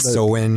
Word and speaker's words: Samhain: It's a Samhain. Is that how you say it? – Samhain, yeah.